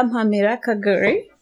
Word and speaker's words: Mpamira-Kaguri: Don't be killing our name like that. That is not how you pronounce that. – My bad. – Thank Tabitha. You. But welcome, Tabby. – Thank you. Thank you. Tabitha Mpamira-Kaguri. – There you go.